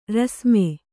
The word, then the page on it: ರಾಸ್ಮೆ: rāsme